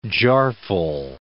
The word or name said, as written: jarful